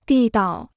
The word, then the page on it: 地道: dì dao